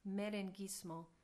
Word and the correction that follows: Merenguissimo: Merenguissimo. – Oh, merenguissimo.